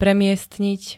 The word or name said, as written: premiestniť